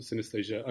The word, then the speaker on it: synesthesia: I read on your website you've got a condition called synesthesia.